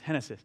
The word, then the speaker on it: Genesis: It's spelled like Genesis, but it's pronounced Genesis.